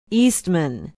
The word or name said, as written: eastman